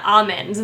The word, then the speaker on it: almond: Yeah, almond. The L is silent.